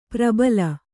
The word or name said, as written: ಪ್ರಬಲಿ